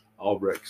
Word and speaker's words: Albrecht: Albrecht.